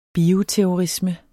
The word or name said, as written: bioterrorisme